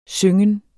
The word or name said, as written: syngen